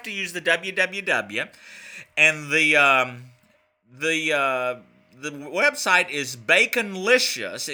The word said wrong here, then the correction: Bacolicious: except it's spelled B-A-C... Oh. No, it's not Baconlicious. It's Bacolicious.